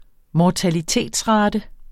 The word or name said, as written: mortalitetsrate